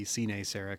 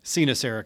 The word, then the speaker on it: Cinesera: So if you were to pronounce it out, it would be Cinesera Capital. But Cinesera Capital rolls off the tongue a little easier, so we go with that.